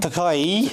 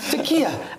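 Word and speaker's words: Türkiye: I need the correct pronunciation. – Takai. – Takai. – Takai. – Takia, actually. No. It used to be Takia.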